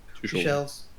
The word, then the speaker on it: Tuchel: Is it Tuchel? I'm pronouncing that one right, aren't I? – Tuchel's. – Tuchel's, yeah. He's probably not going to be the man then, considering they could have.